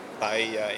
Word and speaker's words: paella: If you want to order a pizza or a paella in the Spanish, pronounce it right as well. Well done.